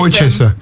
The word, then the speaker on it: Worcester: That one always gets Worcester. – Them. – Worcester. Worcester sauce. – Yeah. Worcester. – Yeah, as opposed to, obviously, Worcester, as we all know it.